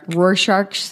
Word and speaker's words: Rorschach: How do you say that? – Rorschach tests.